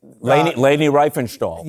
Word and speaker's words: Leni Riefenstahl: Leni Riefenstahl. – Yeah, thanks for the pronunciation.